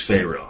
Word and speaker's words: Pharaoh: Why are you using, I'm sure there's a reason, a hard P in the pronunciation of payroll versus Pharaoh? I'm just curious. – I use a hard P because that's what it has in the Hebrew.